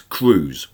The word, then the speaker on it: Cruz: Let's have an unreleased tune, and shall we go to Sheffield for the four-piece Cruise, which is spelled C-R-U-Z. So it's less of a river cruise and more of a Santa Cruz,